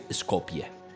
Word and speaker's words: Skopje: Pero la forma de decirse es la misma y es Skopje dicho por ellos mismos.